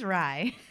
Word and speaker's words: RIE: First of all, what is rye? – So rye is actually an acronym. R-I-E is how it's spelled.